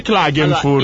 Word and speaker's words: Klagenfurt: Sì, si dice Klagenfurt. – Allora, io non lo so, già la pronuncia italiana sbagliata. – È già di figura di quella tedesca austriaca.